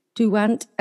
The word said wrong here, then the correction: Daunt: L L. Oh, Tony Daunt. Tony Daunt. okay, what's the at L on the end, or was that a mistake?